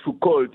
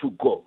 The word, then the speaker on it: Foucault: I see that sometimes students pronounce it as Foucault. It's fine. We don't have to speak French. They don't speak our language. But it's Michel Foucault.